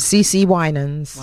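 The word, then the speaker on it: CeCe Winans: Is it pronounced C.C. Winans? – Yep, C.C. Winans. – Winans. – Yeah.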